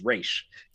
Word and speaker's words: Ra's: no, that's a misconception. It's pronounced Ra's.